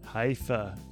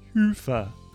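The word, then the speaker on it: Hyvä: Talk Commerce is sponsored by Haifa, or as the Europeans say, Hufa, or the right way to say it.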